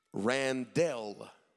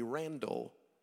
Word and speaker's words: Randell: My name, my first name properly, is Randell. R-A-N-D-E-L-L. I started going by Randy when I was young because everybody mispronounced my name and would call me Randall. While Randall is a good name, it's not my name.